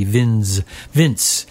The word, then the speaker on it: Vinz: He spells his name V-I-N-Z, Vince, but it is pronounced Vince De Rosa.